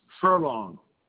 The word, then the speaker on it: Furlong: James Furlong. Spelled like F-U-R-L-O-N-G, like in horse races.